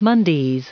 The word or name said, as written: mondays